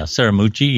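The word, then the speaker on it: Scaramucci: How do you say this? Anthony Saramucci? – Yeah, Saramucci. Yeah.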